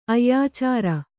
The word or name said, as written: ಅಯ್ಯಾಚಾರ